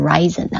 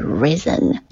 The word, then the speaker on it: risen: 注意一下这个 risen，它是 rise 的过去分词，读音一定不要读成 risen 啊，它是 risen。